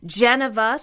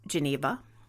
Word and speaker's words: Geneva: Geneva, stressing the first syllable, would be like Jennifer or Geneva. Mm-hmm. Liana suggested Evren, E-V-R-E-N, which I think is really cool. Rowan said Truett, T-R-U-E-T-T, and Neva, N-E-V-A.